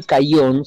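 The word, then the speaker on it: Kajon: Irene Caillón, se escribe Cajón con K y con J, que tiene un libro que se publicó en Buenos Aires, editorial Lilmod.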